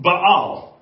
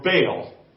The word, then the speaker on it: Baal: Then under El was this god that they called Baal, or Baal, is kind of how us Americans say it.